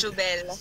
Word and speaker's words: Jobelle: Alors, le nom d'artiste, c'est Jobelle. Est-ce qu'il monte bien prononce lui? Est-ce qu'il est tout bon jusqu'à l'air là? – Oui, bien bon, c'est Jobelle.